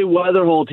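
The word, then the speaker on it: Wetherholt: Is it Weatherholt? Is that his name? Is that how it's pronounced? – Yeah, J.J. Weatherholt.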